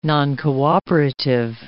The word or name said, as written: noncooperative